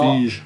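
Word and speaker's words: Wigge: No, Wiege.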